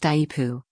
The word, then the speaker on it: IEEPA: called IEEPA, and pronounced IEPU, by sophisticated types, creates the authority to impose sweeping taxes on imports from just about everywhere on Earth.